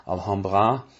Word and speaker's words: Alhambra: it is pronounced Alhambra in German, but it is not a German place name, it's a foreign place name, so I think that all indigenous pronunciations are absolutely okay. You could pronounce it English for English-speaking people. You could pronounce it French, Alhambra. You could say Alhambra. You could say Alhambra. Whatever you want.